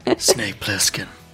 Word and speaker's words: Snake Plissken: No, you have to say it properly. – The Snake Plissken. – That's not good. – Snake Plissken. – Yeah, that's better.